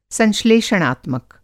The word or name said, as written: संश्लेषणात्मक